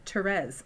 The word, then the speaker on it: Thérèse: Thérèse, mm-hmm. c'est français. Mais en anglais, je ne suis pas Teresa. Je suis Thérèse.